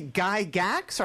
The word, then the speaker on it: Gygax: Is it Gary Gygax, or how do you pronounce it? – As far as I know, that is correct.